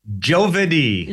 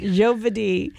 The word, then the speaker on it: giovedì: So we know our narrator as our young man, or as, okay, help me with the pronunciation for Italian for Thursday. How do you, I don't remember. – Giovedì. – Giovedì, which is Giovedì.